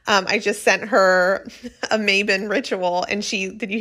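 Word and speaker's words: Mabon: But it is Welsh. The background is Welsh. And so I do like, you know, to pronounce it as close to that as possible, which I believe is Maybon.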